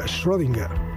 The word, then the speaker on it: Schrödinger: Simplemente como se lee, se pronuncia. No tiene ningún problema. Schrödinger.